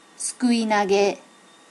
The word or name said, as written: sukui nage